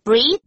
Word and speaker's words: breathe: 所以注意哦，两个字长得很像 breathe, breath, 可是发音是不一样的。